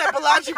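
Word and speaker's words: Mobolaji: I'm like, just say Balaji, bro. I've had to lie. I swear, I've had to lie. The M-O is silent because it's the easiest way to explain it.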